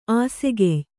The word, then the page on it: ಆಸೆಗೆಯ್: āsegey